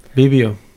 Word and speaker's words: Bibio: How do you say that? – Petals. – No, I know that, but Bibio. – Bibio. Bibio.